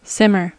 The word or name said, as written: simmer